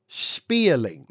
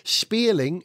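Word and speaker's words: Spéirling: The car is called the McMurtry Spearling. Now, it's spelt M small c M U R T R Y. Yes, it's Irish. McMurtry Spearling is spelt S P E I R L I N G.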